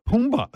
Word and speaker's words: Pumbaa: And then you were Pumbaa.